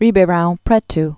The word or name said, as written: Ribeirao Preto